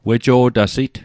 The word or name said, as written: wejo'tasit